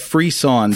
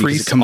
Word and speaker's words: frisson: So I've been saying in my head frisson, because frisson. It comes from the French.